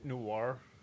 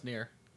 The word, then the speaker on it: Nier: Isn't it Noir? – No, it's Nier. – Is it? – Yeah, N-I-E-R.